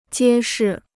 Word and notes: 揭示: jiē shì

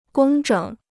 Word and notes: 工整: gōng zhěng

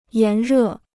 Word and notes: 炎热: yán rè

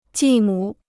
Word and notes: 继母: jì mǔ

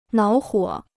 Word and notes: 恼火: nǎo huǒ

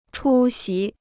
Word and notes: 出席: chū xí

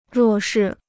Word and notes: 弱势: ruò shì

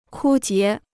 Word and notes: 枯竭: kū jié

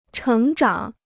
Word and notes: 成长: chéng zhǎng

